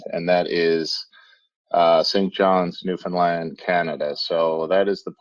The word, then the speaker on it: Newfoundland: So it's understand Newfoundland. You get the alliteration and the flow. So work with that anytime you have trouble.